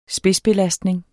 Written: spidsbelastning

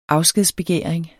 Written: afskedsbegæring

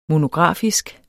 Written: monografisk

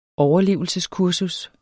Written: overlevelseskursus

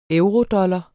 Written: eurodollar

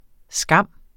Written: skam

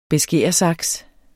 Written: beskæresaks

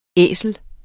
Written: æsel

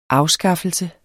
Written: afskaffelse

